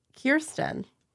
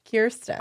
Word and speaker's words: Kirsten: Kirsten. – Wait, what? – It's like Kier underscore Sten. Kirsten.